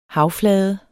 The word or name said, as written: havflade